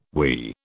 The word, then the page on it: whee: British English